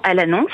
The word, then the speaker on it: Al-Anon: Donc elles ont créé l'association À l'annonce, ça s'écrit A-L-A-N-O-N.